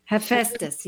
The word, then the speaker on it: Hephaestus: Hephaestus.